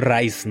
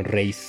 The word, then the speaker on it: Reis: Sí, no Rice, ¿no? Sino Reis. Reis, ajá.